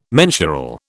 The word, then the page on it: mensural: British English